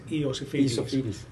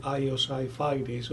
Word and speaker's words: Iosifidis: So it is Iosifidis. But most of the English pronounce me Iosifidis or something, which is kind of Wi-Fi, high fidelity or something.